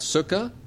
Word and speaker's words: Sukkah: This first one is Sukkah City. That is, I'm pronouncing it correct, Sukkah. – Yeah, Sukkah. – Sukkah, and not being of the persuasion, you can correct me here.